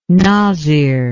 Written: nazir

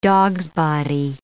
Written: dogsbody

